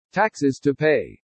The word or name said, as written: TAXES TO PAY